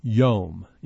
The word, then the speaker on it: yom: The Hebrew word that we're talking about here is the word yom. In English, we might spell it Y-O-M with a long O.